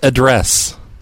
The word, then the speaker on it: address: It's pronounced address. – Address? – The accent is on the second syllable, you big dummy.